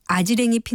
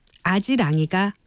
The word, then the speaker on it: 아지랑이: 또 아지랑이 피는 봄날과 아지랭이 피는 봄날 가운데 어느 것이 맞을까요? 아지랑이가 맞는 표현입니다. 이는 이 역행 동화 현상에 의한 발음은 원칙적으로 표준 발음으로 인정하지 않는다는 규정에 따른 것입니다.